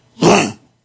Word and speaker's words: Rennes: Rennes.